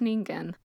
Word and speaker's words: Ningen: but all of the stuff on the actual word is pronounced Ningen. So we're going to go with Ningen. – Yes, because it is a Japanese word.